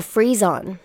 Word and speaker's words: frisson: Oh, Frisson. It's pronounced Frisson.